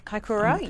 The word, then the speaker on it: Kaikoura: In Kaikoura. I think the Oh. – town, I can't pronounce it, Kaikoura. – Kaikoura, yes.